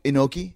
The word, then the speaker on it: Inoki: And Iannucci. Is that how you pronounce his name? – Inoki. – Inoki. – Yeah. – There you go. – Yeah, Inoki.